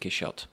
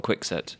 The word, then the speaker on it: Quixote: French pronounce it Quixote with the CH. But I don't understand that. I think it's the logic behind English people saying Don Quixote because we say Quixotic. – I don't think it's that deep. I think it's literally just people pronouncing what is in front of them. – Yeah. – And in English, the X is just a K.